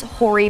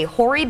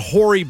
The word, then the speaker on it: hoary: This is Horry Potter, a hoary bat. – I'm sorry. – Horry Potter. It's a hoary bat. It's spelled with an H.